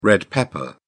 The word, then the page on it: red pepper: British English